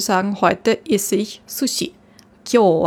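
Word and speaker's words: Kyo (今日): Und wenn man es in Kanji schreibt, dann spricht man es aber nicht Konnichi aus, sondern Kyo. Kyo heißt heute.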